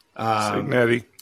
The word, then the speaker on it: Cignetti: And then for the Sun Belt, I put Cignetti, or whatever the hell you say his name. – Cignetti,